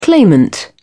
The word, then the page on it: claimant: British English